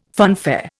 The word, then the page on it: funfair: Indian